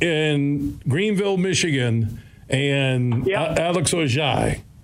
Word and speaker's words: Orji: And I think his real name, I'm looking at the enunciations and pronunciation guide for Michigan football. It's Ojai. – Ojai.